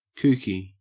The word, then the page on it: kooky: Australian English